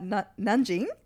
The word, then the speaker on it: Nanjing: He, oh, interestingly enough, lived in, you're going to like hate me for mispronouncing this, at Nanjing. – That's pretty good. – That's pretty good. – Okay, okay. Andrew and Mark are both Chinese, by the way. yeah, for some time, his mum taught at Nanjing University and he picked up the language but has since forgotten most of it, apparently.